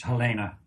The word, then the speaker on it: Helene: Yeah, it's her dad's German, so it's pronounced Helena.